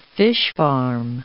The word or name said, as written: fish-farm